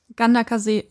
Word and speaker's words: Ganderkesee: Ganderkasee, ja? Ja, genau. Anscheinend spricht man das so aus.